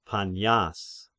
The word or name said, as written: Panias